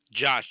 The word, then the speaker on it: Josh: Say your name was Josh, J-O-S-H. We're Deluxe Home Care. J-O-S-H. J-O-S-H.